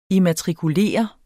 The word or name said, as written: IMMATRIKULERE